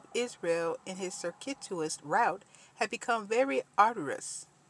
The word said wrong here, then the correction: arduous: Okay, the word I pronounced it arduous is actually arduous, spelled A-R-D-U-O-U-S.